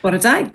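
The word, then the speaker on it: Bore da: But for now, Borodar. And is that right? Did I say that right? Borodar? – Borodar, good morning.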